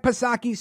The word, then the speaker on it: Psaki: Now, of course, Jen Circleback Psaki, Silent P, she couldn't report on anything until 9:30 a.m.